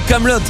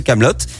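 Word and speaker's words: Kaamelott: Et en point info, Camelot s'écrit avec euh, deux A en référence à ses initiales, A et il a ajouté un T à la fin, juste pour être sûr qu'on prononce bien Hot et pas euh, Camelot.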